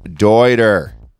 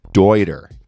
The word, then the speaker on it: Deuter: Dude, it's pronounced Deuter.